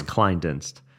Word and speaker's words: Kleindienst: It's Kleindienst. So when I tell people, I'm like, hey, it's like, think of Calvin Klein, Dienst.